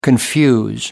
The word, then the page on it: confuse: American English